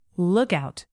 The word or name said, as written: lookout